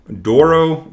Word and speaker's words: Douro: And it's generally exclusively from the Douro Valley, and that's D-O-U-R-O, in case I am totally mispronouncing that, and I apologize. – No, that's how I would say it. – Okay. And it's from the northern province of Portuga. Portuga? P-O-R-T-U-G-A.